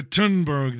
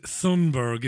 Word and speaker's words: Thunberg: Greta Thunberg there. I had to stop pronouncing the H. It isn't Thunberg, it's Thunberg, yeah?